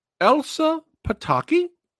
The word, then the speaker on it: Elsa Pataky: Now, Elsa Pataky? – Yep.